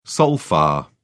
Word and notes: sol fa: British English

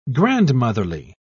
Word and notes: grandmotherly: British English

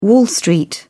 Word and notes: wall street: British English

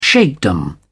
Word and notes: sheikhdom: British English

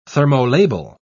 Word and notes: thermolabile: British English

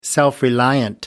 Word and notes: self reliance: American English

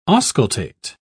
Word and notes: auscultate: British English

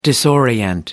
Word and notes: disorient: British English

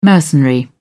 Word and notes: mercenary: British English

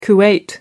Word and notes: kuwait: American English

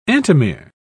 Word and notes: antimere: British English

